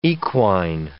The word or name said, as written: equine